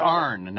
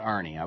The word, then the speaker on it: Arne: It's Arne, not Arnie. I was mispronouncing it.